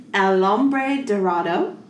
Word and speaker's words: El Hombre Dorado: So it is originally known as El Hombre Dorado.